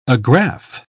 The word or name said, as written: agrafe